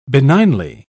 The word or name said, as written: benignly